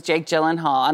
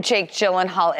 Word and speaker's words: Jake Gyllenhaal: Rhymes with Hake Billenhall. – Yes. So I woke up Sunday morning and trending on Twitter was Jake Gyllenhaal.